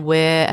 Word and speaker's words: Ware: So Bronnie is like Bonnie with an R in it. Bronnie. And Ware as in like Bunnings Warehouse.